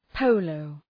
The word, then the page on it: polo: ‘pəʋləʋ